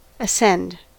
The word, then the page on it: ascend: American English